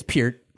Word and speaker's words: Peart: Neil Peart or Pert, however you pronounce it. – It's Peart.